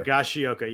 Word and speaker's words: Higashioka: Higashioka was available in a lot of leagues. Higashioka, I always added a syllable there. – Higashioka, yes.